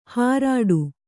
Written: ಹಾರಾಡು

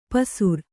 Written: ಪಸುರ್

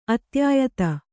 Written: ಅತ್ಯಾಯತ

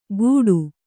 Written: ಗೂಡು

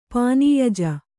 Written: ಪಾನೀಯಜ

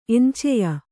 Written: ಎಂಚೆಯ

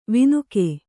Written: ವಿನಕೆ

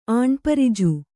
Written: ಆಣ್ಪರಿಜು

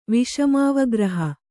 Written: ವಿಷಮಾವಗ್ರಹ

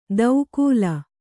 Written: ದೌಕೂಲ

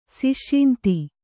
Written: ಸಿಷ್ಯಿಂತಿ

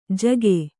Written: ಝಗ

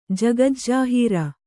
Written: ಜಗಜ್ಜಾಹೀರು

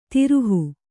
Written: ದ್ರೋಹಿ

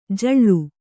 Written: ಜೂಳೆ

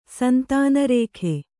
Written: ಸಂತಾನರೇಖೆ

